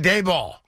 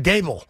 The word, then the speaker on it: Daboll: His last name is actually pronounced Brian Dable, like table. – Right. – Like fable and sable and able.